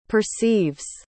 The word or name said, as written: perceives